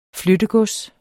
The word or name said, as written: flyttegods